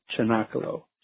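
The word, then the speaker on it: cenacolo: It's pronounced with a hard, with a CH sound, Chinakalo.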